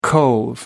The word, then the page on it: cove: American English